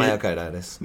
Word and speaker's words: myocarditis: myocard how do you say myocarditis. It? Myocarditis.